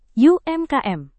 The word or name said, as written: umkm